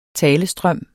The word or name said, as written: talestrøm